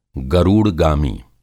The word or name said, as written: गरूड़गामी